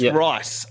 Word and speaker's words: Ryce: It's pronounced Rice, R-Y-C-E, Rice.